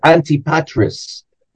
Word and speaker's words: Antipatris: We usually call it Antipatris.